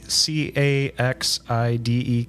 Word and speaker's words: Caxide: C A X I D E Caxide.